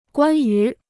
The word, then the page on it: 关于: guān yú